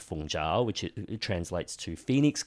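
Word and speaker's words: Fung jiao (鳳爪): Fung jiao.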